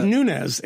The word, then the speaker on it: Nunes: It's just Nunez. It's not that hard. – No, but it should be fucking Nunez. – No, but it's not. Nunez. He's not from like Guatemala.